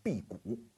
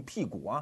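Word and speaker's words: 庇古: 啊，这个争论的始作俑者啊，刚开始挑起它的是一个英国的经济学家，名字叫辟谷啊，名字不能念错啊，不能念成屁股啊。他是一个生在十九世纪。主要学术成就是建立在二十世纪的这么一个英国的经济学家，是剑桥学派的扛大顶的人。那这个辟谷啊，一生创造了所谓的福利经济学。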